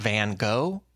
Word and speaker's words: van Gogh: The most common pronunciation you run into in the United States is Vincent van Gogh.